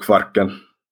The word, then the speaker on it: Kvarken: And the area, you told me the area where you live is called the Kvarken area. Did I say that right? – Yeah, the stretch of water that connects Sweden and Finland is called Kvarken. – Okay, I could never say it like that. But as the American, I'll just say Kvarken. Right? – Yeah. Good enough.